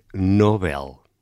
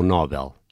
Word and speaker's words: Nobel: Eh, ao que consta é Nobel e ah. não Nobel.